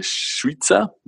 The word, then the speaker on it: Šuica: Und ähm, wenn ich befragt habe, ist äh, Schweizer, so spricht man sie aus, ähm, für ja, Themen zu, ähm, äh, die was mit AFCO zu tun haben, also mit meinem Verfassungsfragenausschuss.